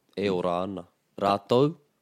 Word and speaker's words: rātou: So if you're on the east coast of the North Island, you'll hear rātau, rātau, T-A-U. The west coast, you're going to hear rātou, T-O-U.